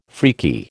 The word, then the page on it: freaky: American English